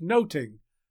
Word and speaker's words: nothing: Nothing was probably pronounced like noting, as in Much Ado About Nothing.